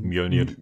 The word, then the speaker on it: Mjölnir: Und, ähm, genau, dann äh, geht's praktisch zu Millionier. Ich kann diesen Namen nicht aussprechen. – Mjölnir. Mjölnir? – Mjölnir. Mjölnir. Mjölnir. Mjölnir. – Ja, ungefähr so.